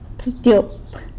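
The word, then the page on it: tsëbtyop: San Lucas Quiaviní Zapotec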